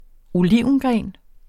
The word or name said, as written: olivengren